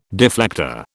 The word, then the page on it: deflector: British English